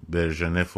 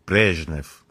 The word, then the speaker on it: برژنف: برژنف و برژنف تلفظ درستش برژنفه خب اینها همه داستان انقلاب اکتبر دیده بودن خب.